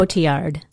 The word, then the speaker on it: Cotillard: How do you say her last name? – Cotillard. – Okay.